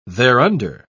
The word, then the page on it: thereunder: British English